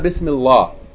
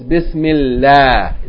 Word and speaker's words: Bismillah: It's not Bismillah, it's Bismillah. It's pronounced Yani La. Because why? Because the vowel before it has kasra. Okay, so pay attention to this difference in the pronunciation of the word. If it's preceded by Dhamma or Fatha, then it is pronounced heavy. And if it's preceded by kasra, then it's pronounced light.